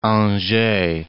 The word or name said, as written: Angers